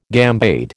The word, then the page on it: gambade: American English